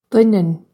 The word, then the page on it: Doineann: duinn-un; Irish